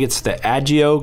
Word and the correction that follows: Adagio: glider that you guys have coming. Is that correct, the name? – Adagio. – Adagio.